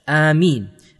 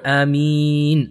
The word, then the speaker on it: Ameen: Also, we have to realize that the proper way to pronounce Ameen is basically as such, Ameen. And if we make it longer, Ameen, this is also allowed. However, it is not allowed to make the alif long. We are not allowed to say Ameen because that changes the meaning.